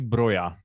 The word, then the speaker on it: Broja: jak bychom si mohli sladit tady naše broža nebo brocha. Koukám, že eh, albánská výslovnost je podle Wikipedie broja, eh, tak shodneme se na tom, abychom tady měli situaci ta Norvič a ten Norvič? – No nebudeme říkat Armando.